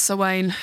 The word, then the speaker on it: Samhain: Enjoy your Samhain. Enjoy the veil between worlds growing thin. Mm. Enjoy the equinox. Enjoy whatever you wish to enjoy. – Every year I tell you it's pronounced Sawain.